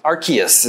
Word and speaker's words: Arceus: Pokemon Legends, is it Arceus? – Arceus is how it's pronounced. – Or Arceus, nobody knows.